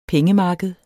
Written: pengemarked

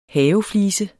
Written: haveflise

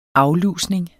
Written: aflusning

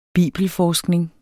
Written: bibelforskning

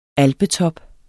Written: alpetop

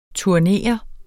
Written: TURNERES

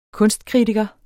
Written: kunstkritiker

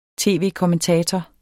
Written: tv-kommentator

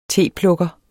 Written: teplukker

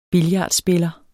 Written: billardspiller

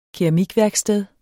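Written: KERAMIKVÆRKSTED

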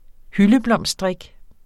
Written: hyldeblomstdrik

